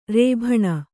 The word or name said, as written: ರೇಭಣ